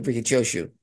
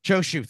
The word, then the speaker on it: Choshu: Yeah, Ricky Choshu. Yeah. – Choshu, thank you. I knew I was pronouncing it wrong, and I figured if I just threw it out there, you'd have it.